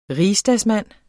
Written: rigsdagsmand

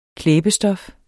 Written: klæbestof

